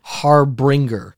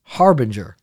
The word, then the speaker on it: harbinger: I've always pronounced it harbinger, but it's actually pronounced harbinger.